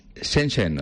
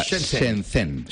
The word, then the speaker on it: Shenzhen: Shenzhen, o sea, Shenzhen.